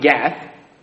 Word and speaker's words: Gath: He says, "Tell it not in Gath," which in Hebrew, Targit is the "tell it not," and Gat is how you pronounce Gath.